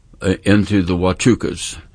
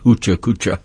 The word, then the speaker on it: Huachuca: So I wanted to get to know more about it, so I went down and I went into the Huachucas, spelled H-U-A-C-H-U-C-A, pronounced Huachuca, not Hucha Kucha.